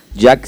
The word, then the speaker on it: Jack: Jack se escribe J-A-C-K.